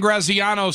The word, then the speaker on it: Graziano: Dan Graziano says the Packers... – Are we saying his name correctly? – Yes.